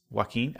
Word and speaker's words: Joaquin: I know that his name is Joaquin, and I know that it's pronounced that way,